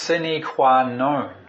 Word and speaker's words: sine qua non: It's pronounced sine qua non. It looks like non, but we pronounce non. So it's sine qua non. – Latin. 这个黑板上所写的这个字呢是拉丁文哈。 – We say crucial, something that is crucial or necessary. – 好，第一行的这个拉丁文哈，sine qua non，它其实就是说非做不可的，好，就是非常必要，一定要做的。